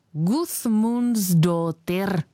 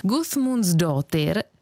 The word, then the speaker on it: Guðmundsdóttir: Toto písmenko se vyslovuje podobně jako anglické V ve slově this. A představ si, že stejné písmenko má ve svém jméně i Björk Guðmundsdóttir.